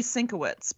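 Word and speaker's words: Sienkiewicz: He's the comic, it's probably Sinkovich, because it's like Mankiewicz.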